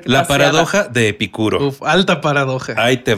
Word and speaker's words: Epicuro: Pues bueno, hablando de, espero pronunciarlo bien, Epicuro, ¿está bien dicho? Claro.